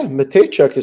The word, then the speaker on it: Mateychuk: Denton Mateychuk goes out injured. Did I pronounce it right? – Yeah, Mateychuk is how you say it. You got it.